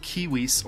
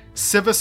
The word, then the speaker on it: civis: This derives from the Latin word civis, or civitas, which are supposed to be pronounced kiwis or kiwitas, but no one pronounces them that way.